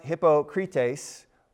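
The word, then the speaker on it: hypokritēs: It's pronounced hypokrites, and it means really close to our word, hypocrite, right?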